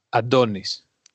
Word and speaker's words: Antonis: It's, yeah, the second one. And it's Adonis.